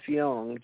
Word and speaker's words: Jung: But the psychoanalyst Carl Gustav Jung, J-U-N-G, we said Jung because, you know, we're from the South, Y-O-U-N-G, but it's J-U-N-G. You pronounce the J like a Y.